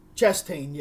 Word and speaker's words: Chastain: Chastain, yeah. That's how you pronounce it.